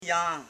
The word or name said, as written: jan